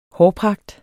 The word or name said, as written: hårpragt